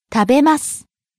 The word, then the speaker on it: 食べます: 食べます. – To eat. Mas form. – 食べます. 食べます. – Starting low, rising on the second syllable. べ. Falling on the last syllable. す.